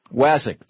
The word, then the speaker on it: Wasik: Wasik, thank you.